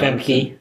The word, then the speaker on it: Famke: Is that how you say it? Famke Janssen? – Yeah, Famke.